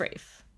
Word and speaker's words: Ralph: Is it Rafe or Ralph? Am I saying it wrong? – It's Rafe.